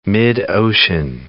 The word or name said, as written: mid-ocean ridge